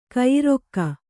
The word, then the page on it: ಕೈಯಾರೈಕೆ: kaiyāraike